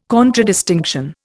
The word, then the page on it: contradistinction: Indian